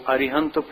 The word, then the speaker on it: अरिहंत: देखो अरिहंत पद के 3 नाम हैं। अरिहंत एक नाम ये हुआ, अरहंत दूसरा हो गया, अरुहंत 3 हैं। ये तीनों पद सही हैं, इसमें से किसी का भी उच्चारण कर लो तो गलत कुछ भी नहीं है।